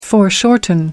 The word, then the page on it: foreshorten: American English